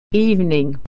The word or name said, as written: evening